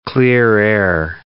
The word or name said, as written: clear-air turbulence